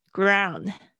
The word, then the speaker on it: ground: all right, next one is ground temperature 这个也是，excuse me，这也是在机长的时候可能会说的这个 ground ground 哦，就是地面。大家注意一下 o u 这个发音，我估计很多人会念不好。